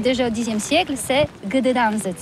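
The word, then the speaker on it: Gdańsk: Ça s'écrit G-D-A-N-S-K. On dit Gdansk ou Dansk, on prononce le G ou pas?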